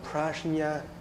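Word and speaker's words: prajñā: Và cái trí huệ đó đó, người Ấn Độ thì họ không đọc là trí huệ, họ đọc là prajna.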